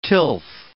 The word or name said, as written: tilth